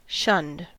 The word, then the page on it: shunned: American English